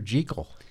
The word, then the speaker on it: Jekyll: The only one where they actually say Jekyll. – And I was outraged. I thought, oh, these idiots, they're mispronouncing it. And then I looked it up, and it was like, that's how Stevenson pronounced it.